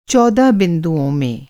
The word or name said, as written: बिंदु